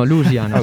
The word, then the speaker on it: Lusiana: Louisiana. No, Lusiana, okay. senza I.